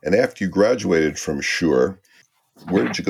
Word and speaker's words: Schurr: It's called Sure High School, like, you know, for sure. But it's spelled S-C-H-U-R-R.